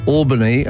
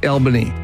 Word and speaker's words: Albany: We went to Albany, and they said, don't call it that. It's Albany. Yeah, I have been there, and they're very keen on that.